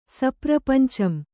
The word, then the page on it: ಸಪ್ರಪಂಚಂ: kannada